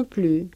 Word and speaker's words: plus: Non plus, moi aussi, avec le pronom personnel tonique. Vous faites une chose, moi aussi. Je ne fais pas ça, moi non plus. Voilà, on ne prononce pas les S dans ces cas-là, hein, attention.